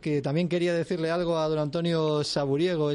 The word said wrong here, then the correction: Sabugueiro: este hombre que nos trajo la San Silvestre. – Bueno, Sabugueiro, que lo he dicho mal.